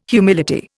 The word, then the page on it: humility: Indian